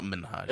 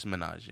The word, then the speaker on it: Minhaj: It's not Minhaj. – It's Minaj? – It's Minaj, yeah. – Minaj. – Yeah. – Even if you're... – Hasan. Hasan Minhaj. – Hasan Minhaj. Yeah. Hasan Minhaj, so you don't pronounce the H?